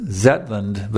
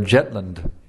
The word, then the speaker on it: Zetland: Actually, it is Lord Zetland, but Bengalis don't pronounce the Z, so Prabhupada said Lord Jetland.